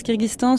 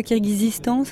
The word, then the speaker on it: Kyrgyzstan: Ouais, alors soit tu le prononces Kyrgyzstan, soit Kyrgyzistan, ça s'écrit Kyrgyzistan, mais après ça dépend de chacun.